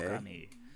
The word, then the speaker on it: Okami: Okami.